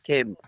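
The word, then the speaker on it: Keem: Anyway, Kim Beach, there are different pronunciations, but the locals here, they've told me it's Kim.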